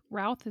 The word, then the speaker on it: Routh: Kim Sistad and Superman, Brandon Routh. Routh, is that how you say his name? – Yeah, he needs to be in more Hallmark movies.